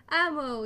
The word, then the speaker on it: Amo: So I got a few responses, and we'll start with the first question from, I think you pronounce it Ammo? A-M-O? – Ammo, yeah. – Ammo, yeah, okay.